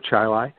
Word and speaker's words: Chile: Rhymes with Hi-Li. I grew up on a dirt road called Stallman Drive. It was off of Ballantyne Road, south of the city of Rochester, in the town of Chile.